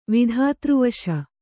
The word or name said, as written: ವಿಧಾತ್ರವಶ